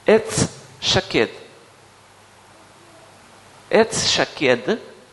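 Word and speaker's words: шакед (שָׁקֵד): Слово «миндаль» «шакед» и слово «бодрствовать» или «контролировать» «шокед» выглядит одинаково в иврите. Одинаково. Здесь пишется «шакед», а здесь «шокед».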